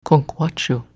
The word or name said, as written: Kunckwatchu